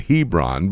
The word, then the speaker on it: Hebron: It's Hebron, H-E-B-R-O-N, and I know in Georgia they pronounce it Hebron, but the Hebrew B has a V sound.